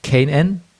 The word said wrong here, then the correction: QAnon: Kanan, weißt du die für? – QAnon. – QAnon spricht man das? – Ja, ich glaube.